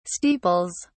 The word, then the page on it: steeples: British English